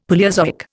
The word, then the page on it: Palaeozoic: Indian